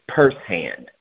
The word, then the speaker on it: Pursehand: So this is purse hand. Okay. – Uh-huh. – It rhymes with firsthand.